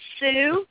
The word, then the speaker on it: Sue: My name is Sue. Yeah, and it rhymes with what you're saying.